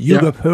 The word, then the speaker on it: Hugh Hopper: Ouais, je vais clore ma section, si j'ose dire, avec Hugh Hopper, j'imagine qu'on dit Hugh Hopper, yeah. Quand on prononce comme il faut.